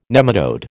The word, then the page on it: nematode: American English